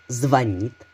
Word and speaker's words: звонит: Звонит, звонишь, звоните. Тут предлагаю запомнить раз и навсегда. Ударение падает на второй слог. Например, филологу никто не звонит, филологу всегда звонят.